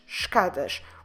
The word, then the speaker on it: escadas: E-S-C-A. D-A-S, escadas. And you see what happens here. Sobes ends at sh. As ends at sh. And escadas starts at sh.